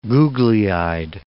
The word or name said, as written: googly-eyed